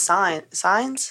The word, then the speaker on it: Sainez: Like my buddy Gus, his last name's Signs, but it's spelled Sainez. So I used to fuck with him all the time. – N-E-Z or whatever? – Yeah. – Yeah. – Yeehaw. – It's pronounced Signs? – Mm-hmm.